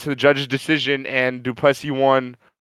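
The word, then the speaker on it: Du Plessis: And Strickland lost the fight to how do you say it, LT? Duplessis? – Duplessis, yeah, he got Duplessis. – It. – Duplessis, okay. All right, Mike was trying to convince me it was Duplessis.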